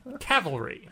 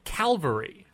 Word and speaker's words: cavalry: Cavalry. – Okay. – I in my head, when I'm reading a book that has the word cavalry in it, I mispronounce it in my head, Calvary.